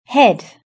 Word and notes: head: British English

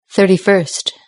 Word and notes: thirty first: British English

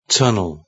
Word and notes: road tunnel: British English

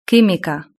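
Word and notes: quimica: Spanish (Spain)

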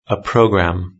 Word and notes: a programme: British English

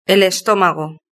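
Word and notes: el estomago: Spanish (Spain)

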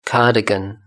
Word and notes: cardigan: British English

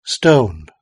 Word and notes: stone: British English

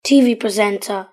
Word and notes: tv presenter: British English

